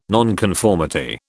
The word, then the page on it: nonconformity: British English